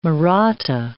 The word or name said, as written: Maratha